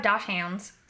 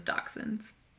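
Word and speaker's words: dachshund: I have Dosh hounds, so I like to say hounds. – You're like the only person that I know that actually pronounces it that way, which I know is correct. – It is. – But everyone else just says dachshunds.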